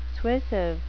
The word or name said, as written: suasive